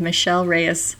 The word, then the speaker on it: Michelle Reyes: Yes, you did, Michelle Reyes.